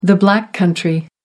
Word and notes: Black Country: American English

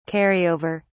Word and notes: carry over: British English

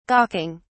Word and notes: gawking: British English

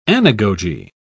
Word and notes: anagogy: British English

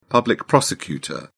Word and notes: public prosecutor: British English

